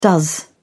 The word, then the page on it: does: British English